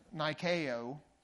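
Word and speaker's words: Nikeo (νίκη): Nike, N-I-K-E. Look it up. It's actually pronounced Nikeo, but in the English it's spelled N-I-K-E.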